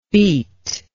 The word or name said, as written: beetlike